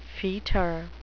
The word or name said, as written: fetor